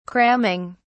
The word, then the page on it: cramming: British English